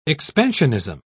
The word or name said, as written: expansionism